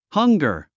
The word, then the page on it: hunger: hʌ’ŋgə r; ハンガァ